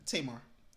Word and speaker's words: Tamar: If you guys do not know, it was Tamar, right? – Tamar. That's how you say her name.